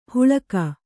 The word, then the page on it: ಹುಳಗ: Kannada